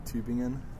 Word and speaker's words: Tübingen: Tübingen. It's like with the okay. – U, with the 2 points above the U.